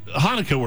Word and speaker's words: Hanukkah: So Hanukkah works. It's for an English mouth, that's about as close as you're going to get, because you'll sometimes see it spelled with a CH instead of an H.